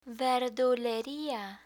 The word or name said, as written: verduleria